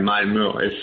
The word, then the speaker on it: Malmö: Se dice, la O del final es un poco complicada, es como una O que se pronuncia como una E, Malmö, no es fácil, la verdad, de decir.